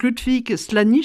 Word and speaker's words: Ludwig Slany: Mais lui, donc Ludwig Slany, je ne sais pas si je prononce comme il faut. – Très bien.